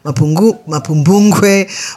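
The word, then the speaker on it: Mapungubwe: I know, now I know actually how to say it properly. Mapumbungwe, Mutapa, and Great Zimbabwe.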